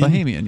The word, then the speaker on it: Bahamian: I'm trying to remember because I think I had an argument with Mark Edge about, Bahamian? Is that how it's pronounced? – I think so, yeah. – Like, you know, if you're from the Bahamas, you're a Bahamian? – Bahamian, yeah.